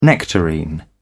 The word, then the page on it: nectarine: British English